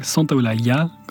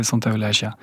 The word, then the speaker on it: Santaolalla: Et comme me le faisait souligner l'ingénieur du son, ça ne se prononce pas Santaolalla en espagnol, mais en argentin c'est Santaolalla.